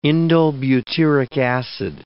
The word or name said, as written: indolebutyric acid